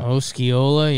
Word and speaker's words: Osceola: I don't know where Oscala, Osceola. How do you pronounce this town? It's a county in Florida. – Osceola, yeah.